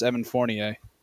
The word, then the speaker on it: Fournier: And someone corrected me that spoke French, and it's Evan Fournier.